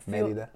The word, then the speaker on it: Mérida: There's some like a gringo. Mérida. – Mérida. Yeah. With an accent in the E, the first E.